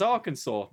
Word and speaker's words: Arkansas: It's like, it's Arkansas. That's not how it's spelled, mate.